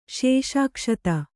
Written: ಶೇಷಾಕ್ಷತ

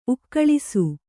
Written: ಉಕ್ಕಳಿಸು

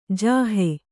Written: ಜೋಹು

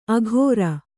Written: ಅಘೋರ

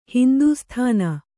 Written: ಹಿಂದೂಸ್ಥಾನ

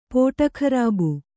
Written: ಪೋಟಖರಾಬು

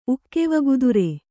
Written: ಉಕ್ಕೆವಗುದುರೆ

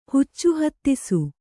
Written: ಹುಚ್ಚುಹತ್ತಿಸು

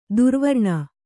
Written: ದುರ್ವ್ರಣ

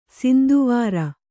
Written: ಸಿಂಧುವಾರ

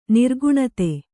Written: ನಿರ್ಗುಣತೆ

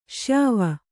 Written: ಶ್ಯಾವ

